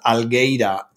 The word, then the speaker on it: Algueira: There's a winery called Algeira, A-L-G, Algeira, I'm going to spell it wrong. A-L-G-U-E-I-R-A.